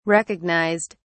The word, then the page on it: recognised: British English